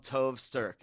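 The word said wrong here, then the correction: Tove Styrke: Tove Sterke.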